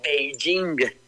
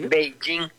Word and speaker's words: Beijing: P-E-K-I-N. Ajá. Cuando la capital de China se pronuncia Beijing. – Sí. – Beijing. Correcto. Pero era una forma en que los españoles lo traducían, ¿me explico?